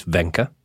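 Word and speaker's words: Wenche: His mother, it's spelt wench, but we have learnt that it's Venka. His mother, Venka Behring, was a nurse who spent the first year of Anders' life bringing up the family in London.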